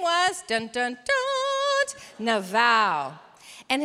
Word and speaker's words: Nabal: Your Bible spells it N-A-B-A-L, but I want you to repeat after me the Hebrew pronunciation, which is Naval. Repeat after me, Naval. Okay, so when we hear Naval, this is what I want you to think.